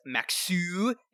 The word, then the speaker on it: Maxsø: Second player that the Rapids signed chronologically, Mark, was Danish center back Andreas Maxu, is how it is pronounced there, folks.